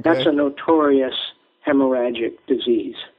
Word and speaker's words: hemorrhagic: Hemorrhagic. – Yeah. – Hemorrhagic? – Hemorrhagic.